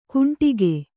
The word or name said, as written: ಕುಂಡಿಗೆ